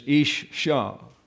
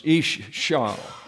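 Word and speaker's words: Ish Shah (אִשָּׁה): So the correct pronunciation, because of all these dots, is Ish Shah.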